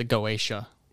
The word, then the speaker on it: Goetia: And that might be synonymous with the Goetia, which is like... – I'm sure that's how it's pronounced. – G-O-E-T-I-A.